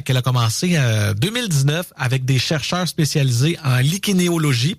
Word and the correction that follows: lichénologie: parce que oui, c'est un mot que j'ai mal prononcé, lichénologie, voilà.